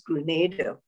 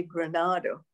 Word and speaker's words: Grenada: First, I'll comment on the fact that you pronounce Grenada beautifully. – Oh, thank you. – So many people tend to say Granada, which of course is a place in Spain.